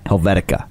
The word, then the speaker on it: Helvetica: Helvetica.